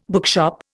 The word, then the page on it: bookshop: Indian